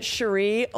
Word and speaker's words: Cherie: But I pronounce it Cherie only because Cherie Curry from The Runaways pronounced it like that.